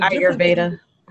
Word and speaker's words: Ayurveda: the Ayurveda, Ayur, Ayur, I can not never say it right. I, I, the, the, the Ayurveda.